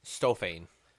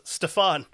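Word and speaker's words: Stiofáin: STIOFAIN. Tell me how you'd pronounce that in English. – STIO. – Yep. – FAIN. – Yes, sir. – I'm gonna go with Stofane. – Oh, you're so close. It's Stefan.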